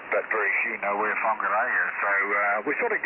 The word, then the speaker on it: Whangarei: Auckland is much easier to say than Whangarei, which is the place we come from.